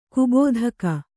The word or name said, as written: ಕೊಬ್ಬುದೆಕ್ಕೆ